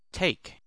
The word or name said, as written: take